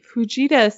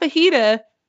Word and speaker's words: Fujita: So, the way we track tornadoes is the Fujita scale, not fajita, and it is also known as the it's F pronounced scale.